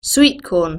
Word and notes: sweetcorn: British English

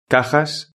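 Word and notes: cajas: Spain

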